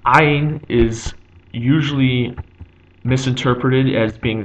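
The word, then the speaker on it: Ayin (עין): Ayin is also silent, but Ayin you pronounce by constricting your vocal cords. So if there's a vowel attached to ayin, you would say the vowel while constricting your vocal cords in kind of like a guttural gulping sound.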